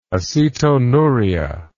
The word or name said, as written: acetonuria